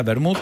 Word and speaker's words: Wermut: yo no sé cómo se pronuncia, pero se escribe wermut. – Vermut. – Ok, ahí está, bien, ahí, con acento en la W.